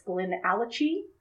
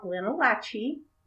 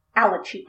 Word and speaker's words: Glenallachie: It's Glenallachie, not Glenallachie. – Glenallachie.